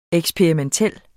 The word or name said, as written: EKSPERIMENTEL